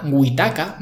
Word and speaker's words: Wetaca: Witaka, ¿vale? Que se escribe W-E-T-A-C-A,